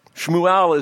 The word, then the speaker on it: Shmuel: Shmuel is his Hebrew pronunciation.